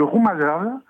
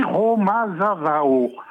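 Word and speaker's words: romazava: Alors ça s'écrit Romazavao. – Très bien. – Mais en malgache, on ne prononce pas le AO. – Ah bah non, en malgache, on ne prononce pas le AO. – On termine sur le V, le roumazave.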